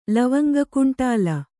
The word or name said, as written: ಲವಂಗಕುಂಟಾಲ